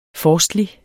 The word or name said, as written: forstlig